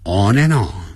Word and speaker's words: on and on: He just plays games on and on. On and on 要连接啊，产生连音，叫一直不断地 on and on。不要念成 on and on。 On and on, 那个 D, A-N-D 的 D, 不发音了 on and on.